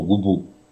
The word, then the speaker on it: губу: И там вдруг оказывается, что в винительном падеже вижу, что губу. Ударение тоже на окончании, а не как слова так.